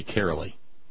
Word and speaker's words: Caroli: I'm supposed to call Caroli Caroli. Says it rhymes with merrily, or so she told.